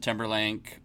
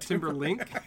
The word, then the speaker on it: Timberlake: And so, you know, if that's Justin Timberlake or... – Timber what? – Timberlink.